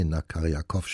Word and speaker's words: Nakarjakow: Sie hörten aus einem Konzert oder dem Konzert in S-Dur den ersten Satz und es spielte hier gerade Sergei Nakarin. Na, jetzt wollen die Silben nicht so locker fließen. Also, Sergei Nakarjakow spielte die Trompete, das St. Paul Chamber Orchestra begleitete ihn dabei.